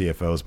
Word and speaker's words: CFO$: Fightful has reached out to WWE and CFO Dollar Sign, but haven't heard back from either. Yeah, I'm pretty sure it's just pronounced CFOs, but it's funny to say Dollar Sign.